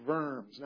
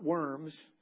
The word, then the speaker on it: Worms: The king was there and everything, and it was called the Diet of Worms, not worms. The W in German is pronounced with a V, and Worms is a place in Germany.